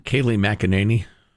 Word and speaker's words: Kayleigh McEnany: Kayleigh McEnany.